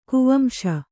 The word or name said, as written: ಕುವಂಶ